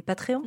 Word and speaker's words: Patreons: Après, on a beaucoup de comptes qui ouvrent aussi tout ce qui est des Patreons.